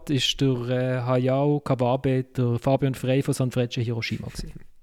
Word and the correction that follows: Sanfrecce: Und offenbar, offenbar spricht man das San Furecce aus. – Oh, Entschuldigung, ja. – San steht für ähm, drei im Japanischen mhm. – und Frecce steht für italienisch Pfeile.